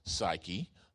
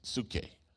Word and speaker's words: psyche: Some pronounce it psyche. Some pronounce it psuche.